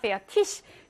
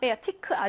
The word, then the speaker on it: fertig: fertig. 티크 이렇게 발음하는 사람들이 독일에도 너무 많은데 요거 표준 독일어 아닙니다. 페어 티시 이렇게 발음하셔야 돼요.